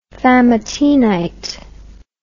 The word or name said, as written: famatinite